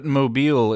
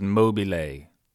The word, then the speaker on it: Mobile: If it were in Italy, it would be pronounced Mobile. In Georgia, mobile, and anywhere else just like the phones to rhyme with global, which is how I imagine it sounds in the mouths of the few Idahoans or Canadians who come here.